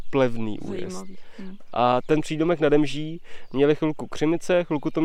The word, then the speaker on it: nade Mží: A skutečně tam není nad Mží, ale nade Mží. – No, aby se to líp vyslovovalo.